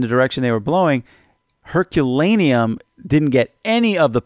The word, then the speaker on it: Herculaneum: Is it Herculean? I feel like I'm saying that wrong. – The other town? – Yeah. – It's Herculaneum.